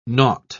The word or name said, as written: naught